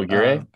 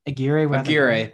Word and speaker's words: Aguirre: Have you written about I'm gonna say Aguirre. I know that's not how it's pronounced. – Oh, Aguirre. – Aguirre.